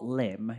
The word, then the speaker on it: Lympne: It's spelled Lympne. Port Lympne. – Is that well? That says Wales to me. Is that Wales? – It's in Kent. – Kent. Okay. – It's in a former Roman spa, so it's a Roman port. I think it's Port Lyme. Is okay. How you meant to pronounce it.